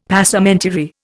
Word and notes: Passementerie: Indian

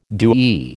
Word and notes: DoE: American English

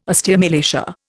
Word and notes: osteomalacia: Indian

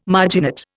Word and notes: marginate: Indian

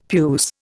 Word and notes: puce: Indian

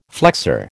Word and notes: flexor: American English

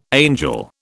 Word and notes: angel: British English

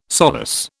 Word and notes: solus: British English

